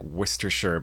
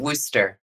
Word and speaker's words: Worcester: I want to say, like, Worcestershire, but that's a totally different place. – No, just Worcester. – Worcester, of course. You couldn't say it like it was written, but yes, Worcester.